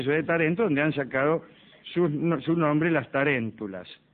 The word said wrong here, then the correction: tarántula: ¿Taréntula? – Tarántula.